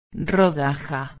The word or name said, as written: rodaja